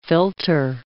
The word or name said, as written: filter